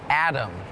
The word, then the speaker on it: atom: ถ้าพี่โบพูดคำว่าอะตอมล่ะรู้จักไหมในวิทยาศาสตร์ใช่เดี๋ยวเราไปถาม professor กันดีกว่านะคะว่าทำไมคำนี้อาจจะ tricky นิดนึง Atom is tricky because it sounds like there's a D in it, Atom. But it's actually A-T-O-M. But it's still pronounced Atom.